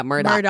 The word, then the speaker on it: Murdaugh: Murdoch. – Murdaugh, but it's Murdoch.